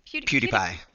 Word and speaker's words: PewDiePie: PewDiePie. – Okay. I was wondering. PewDiePie. – First time I saw it, I thought it was PewDiePie, and I was like, what the fuck is this? – PewDiePie.